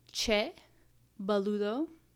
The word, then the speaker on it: Che Boludo: I brought Che Boludo. It's a Malbec from Mendoza, Argentina, 2020. – The way you pronounce that just blew my mind. It sounded really good.